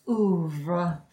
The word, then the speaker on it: oeuvre: You guys are the basic bitches of the Reba oeuvre.